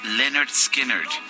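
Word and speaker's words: Lynyrd Skynyrd: Estamos escuchando música de, de este de este grupo, eh, eh, Leonard Skinner me, me, me tocó un poquito de trabajo, se escribe de forma muy peculiar, con Y por todos lados, pero se pronuncia... Con, muchas consonantes, ¿no? – Con muchas consonantes Y en vez de Is, pero es Leonard Skinner.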